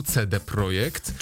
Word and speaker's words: CD Projekt: CD Projekt. – Już błąd, tak? – Tak, oni bardzo zaznaczają, żeby wymawiać ich nazwę po polsku, CD Projekt.